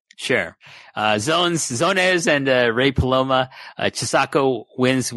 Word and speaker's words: Zones: I've seen the katakana for it, and it's Zones. Z-O-N-E-S-U. Zones. it's in Romaji, or, you know, English cap, all capitals, Zones.